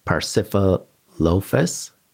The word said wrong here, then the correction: Parasaurolophus: Am I saying that correctly? – Oh, the Parasaurolophus or? – That's bingo. Yeah, bravo.